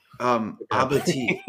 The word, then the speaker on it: abatis: Abati, if you want to say the French pronunciation, how we get intricate in our history here, we call it Abati. – Abati, sorry. – Abati. No, it's okay. I used to call it abatis too, and this one's like, it's Abati. I'm like, oh my gosh, I'm so sorry.